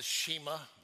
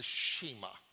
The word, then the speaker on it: Shema: It's called the Shema. That's how I pronounce it. Others pronounce it a little bit differently, but I've always heard it called the Shema.